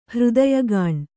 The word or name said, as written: ಹೃದಯಗಾಣ್